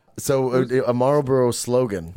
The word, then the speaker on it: Marlboro: It's R and an L, Marlboro. – No, you know, you just need to say it like that. Marlboro.